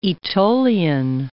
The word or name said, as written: Aetolian